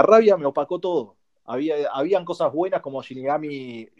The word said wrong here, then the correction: Shin Megami Tensei: no sé cómo se pronuncia, cómo era que se decía. – Shin Megami Tensei.